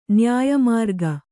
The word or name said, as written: ನ್ಯಾಯಮಾರ್ಗ